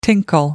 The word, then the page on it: tinkle: American English